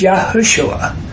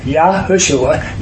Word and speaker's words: Yahushua: No, he named him one name, and it's Yahushua. Four syllables. Yahushua.